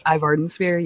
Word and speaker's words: Ivardensphere: It was introduced to me, Bone Dance by Ivardensphere. I think I'm pronouncing that right. Is that how you pronounce it? – Ivardensphere, yes.